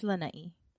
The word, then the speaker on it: Lanai: It's Lanai. We have to pronounce all of our vowels. – That's true. Like, you have to put some emphasis on the E. – The I. It's an I. – Well, it's an I, but the sound is like E.